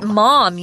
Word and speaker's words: Maugham: Yeah, but when you look at his name, just in your mind, just think M-O-M. That's how it's pronounced. – Close enough, yes. Yeah. It'll make it easy. – William Somerset Maugham, yeah.